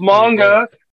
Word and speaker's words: manga: It's manga.